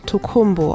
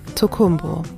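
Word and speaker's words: Tokunbo: Sie schreibt sich lustigerweise mit einem N statt mit einem M. – Aber es wird auf Deutsch Tokumbo ausgesprochen. Mit M, Tokumbo.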